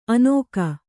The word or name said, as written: ಅನೋಕ